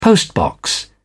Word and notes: postbox: British English

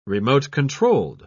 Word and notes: remote-controlled: British English

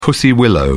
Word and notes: pussy willow: British English

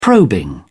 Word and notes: probing: British English

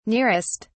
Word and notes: nearest: British English

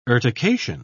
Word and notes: urtication: British English

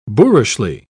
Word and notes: boorishly: British English

